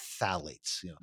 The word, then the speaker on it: phthalates: When you read it, it's actually phthalates. I pronounce it too, but it's actually pronounced with the T, phthalates.